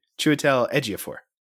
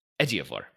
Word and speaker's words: Chiwetel Ejiofor: I think it's Chiwetel Ejiofor. – Ejiofor. Okay.